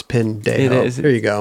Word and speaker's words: pendejo: No, you got to say it right, man. Roger. Pendejo. – My tongue is not going to... Pendejo.